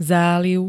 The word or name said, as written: záliv